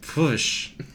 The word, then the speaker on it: Pwyll: But many of the features on Europa are actually Celtic, including its major crater, which is named, or which is spelled P-W-Y-L-L, which is a Welsh name, which I believe is pronounced Pwysh. And please, Welsh listeners, write in and tell me how bad this is.